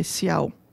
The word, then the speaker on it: ciao: Or Spanish speakers, we just spell it C-H-A-O versus C-I-O-A. – Ah, okay. – Because we're phonetic. So if I were to say C-I-O-A, I would say ciao.